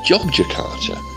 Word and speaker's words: Yogyakarta: Now, even though it's with a Y, it isn't pronounced Yogyakarta, like Bill said, but it's pronounced Jogjakarta, like Ian said.